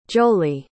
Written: joli

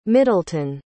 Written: middleton

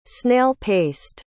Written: snail-paced

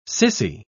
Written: sissy

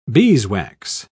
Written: beeswax

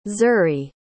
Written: zurie

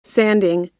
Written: sanding

